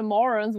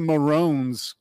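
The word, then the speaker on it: Mörons: And it's pronounced the Maroons. – Maroons. – Yeah, umlaut there. Right.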